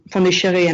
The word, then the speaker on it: Pondicherry: to Pondicherry. And I'll just say in parentheses that I use kind of the French pronunciation of the town because this is what my sources use. Today it's called Puducherry. But